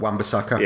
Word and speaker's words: Wan-Bissaka: Wan-Bissaka.